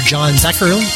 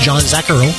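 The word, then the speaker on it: John Zacherle: Heard John Zacharyl. That's how you say it. John Zacharyl.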